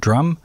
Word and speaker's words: Djrum: All right, next we have a section devoted to the electronic artist called Drum. I always thought he was called DJ Drum because it's DJRUM, but the J is silent.